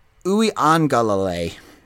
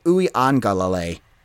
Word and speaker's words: Uiagalelei: So it's DJ Uiagalelei, the quarterback from Clemson. And I want to apologize. And I will get that right for this upcoming season. So DJ Uiagalelei, the quarterback from Clemson. I have been all over that. And just, I think I called him Yukalaga Lele for a while.